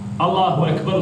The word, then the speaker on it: Allahu Ekber: I to se izgovara sa slovom K. Allahu Ekber. To je od kebir, velik. El Ekber, najveći. Allahu Ekber. Međutim, često ljudi, pa i oni koji su učeni ili se smatraju takvi, izgovaraju umjesto K, G, i govore Ekber.